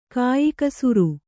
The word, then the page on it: ಕೆಯ್ಕುಸುರಿ: keykusuri